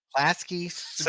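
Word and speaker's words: Klasky Csupo: It's Klasky. – Let me look for it. – Supo, Chupo. – I watched Salute Your Short sometimes. – Klasky Supo. – Say it. Yeah. Okay. Klasky Supo. Supo. Supo. – Klasky. Chupo. – Guys, you gotta stop saying that. – That's the pronunciation. There's the pronunciation in Wikipedia.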